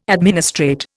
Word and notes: administrate: Indian

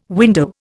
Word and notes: window: Indian

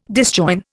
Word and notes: disjoin: Indian

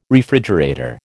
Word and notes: refrigerator: American English

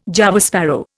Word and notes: Java sparrow: Indian

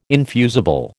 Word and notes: infusible: American English

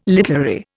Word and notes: literary: Indian

